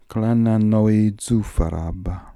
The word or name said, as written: Klananoizufarab